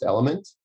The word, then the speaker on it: LMNT: That is spelled L-M-N-T, but it's pronounced Element.